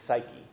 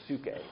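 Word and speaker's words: psuche (ψυχή): That word, as translated in the Greek, is psyche, or psuche, as it gets pronounced normally in ancient Greek.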